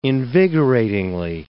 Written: invigoratingly

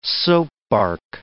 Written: soapbark

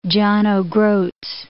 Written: John o' Groat's

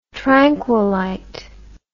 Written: tranquilite

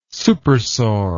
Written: supersaur

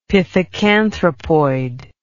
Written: pithecanthropoid

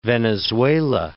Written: Venezuela

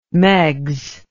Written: meigs

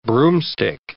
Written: broomstick

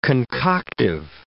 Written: concoctive

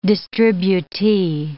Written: distributee

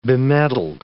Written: bemedalled

